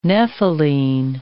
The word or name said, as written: nepheline